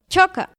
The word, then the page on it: choka: Náhuatl